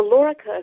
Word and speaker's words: Lorica: I want to talk about, is it pronounced the Lorica or the Lorica of St. Patrick? – The Lorica of St. Patrick, and the Lorica is the part of a suit of armor that protects your chest.